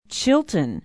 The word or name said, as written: chilton